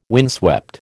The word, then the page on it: windswept: American English